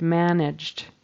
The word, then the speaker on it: managed: And in that particular word managed, the past tense verb, we don't pronounce the whole syllable. We just put a D on the end. Managed, managed. So it kind of goes from that J sound, which is the G spelling, to the D. And the E is just cut off altogether.